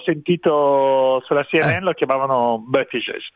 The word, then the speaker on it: Buttigieg: Allora, direi che avrebbe più plausibile Buttigieg.